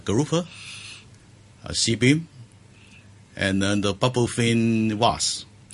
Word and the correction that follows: wrasse: Wrasse. – That's right.